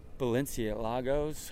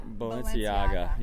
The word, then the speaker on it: Balenciaga: And we own Balenciagos. How do you say it? I don't even know how to say it. – Balenciaga.